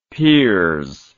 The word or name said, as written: pears